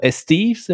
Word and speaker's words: estives: They're called estives. Am I pronounced that? – Yeah, right? The summer pastures are called estive. It's related to the word for summer, ete.